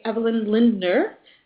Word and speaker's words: Evelyn Lindner: I'm a mediator and conflict coach, and I'm very excited to welcome to the show today Evelyn Lindner. Have I pronounced that correctly? – Yes.